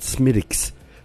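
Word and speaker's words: Smithwick's: And it was called, it's a funny spelling, the way you spell it. But pronunciation of it is, it's called Smiddix.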